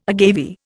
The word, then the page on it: agave: Indian